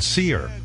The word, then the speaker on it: Cyr: Billy Corgan, who is, of course, out with a new album called Seer. C-Y-R, it's spelled. Seer.